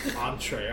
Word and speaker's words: entree: Let me get an entry. He goes, entree. I was like, yeah, but it has an extra e.